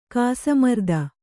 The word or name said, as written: ಕಾಸಮರ್ದ